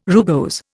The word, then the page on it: rugose: Indian